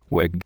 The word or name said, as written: wig